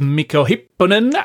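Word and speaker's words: Mikko Hyppönen: You've been talking about secure cryptocurrency wallets with Mikko Hipponen and Peter Ulrich over the last couple of weeks.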